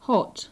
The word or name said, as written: hot